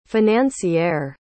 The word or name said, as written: financiere